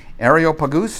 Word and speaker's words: Areopagus: Paul stood up in the meeting of the Areopagus. That's how it's actually pronounced. Weird, huh? Areopagus, and said, "People of Athens, I see that in every way you are very religious.